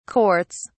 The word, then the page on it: courts: British English